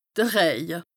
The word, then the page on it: de Rëje: Bas-Rhin; Strasbourg